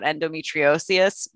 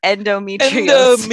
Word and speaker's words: endometriosis: And other than when I briefly fell into like a TED Talk vibe about endometriosis, whoa, I didn't even say that right, about endometriosis. – Endometriosis.